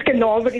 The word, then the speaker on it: Carnarvon: Time heals, if I'm saying it correctly, is it Carnarvon, right? – Yes, it's Carnarvon in the Northern Cape.